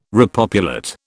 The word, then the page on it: repopulate: British English